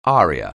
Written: aria

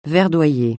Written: verdoyer

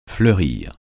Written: fleurir

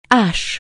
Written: Ashes

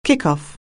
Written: kickoff

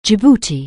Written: Djibouti

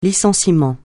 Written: licenciement

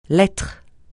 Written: lettre

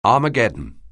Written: Armageddon

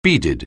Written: beaded

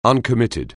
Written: uncommitted

